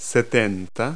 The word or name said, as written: seventy